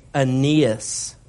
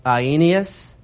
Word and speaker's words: Aeneas: Verse 33, there in Lydda, he found a man named Aeneas. Now that's Aeneas in Greek, but we'll just go with the popular English pronunciation of that, Aeneas, who was bedridden for 8 years, who was paralyzed, verse 34.